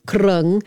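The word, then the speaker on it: kroeung: Okay, so it's krung, it's spelled K-R-O-E-U-N mostly, pronounced krung.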